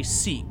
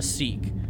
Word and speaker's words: Sikh: Okay, so in this in Star Trek universe, Khan is a Sikh, and I hope I'm saying that right. – You are. – Right, a Sikh, which is a kind of a religious order.